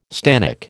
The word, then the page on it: stannic: American English